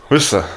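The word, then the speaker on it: Worcester: Muss man ganz schnell aussprechen, dann hat man eine gute Soße. – Wooster.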